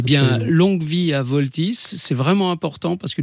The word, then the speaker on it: Vaultys: Alors voilà, pour résoudre ce problème de piratage finalement, Voltys, ça s'écrit VAULTYS, je l'épelle parce que c'est important, voilà.